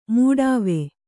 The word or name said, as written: ಮೊಡವೆ